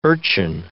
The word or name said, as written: urchin